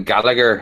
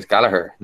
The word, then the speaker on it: Gallagher: my name is Gallagher, not Gallagher.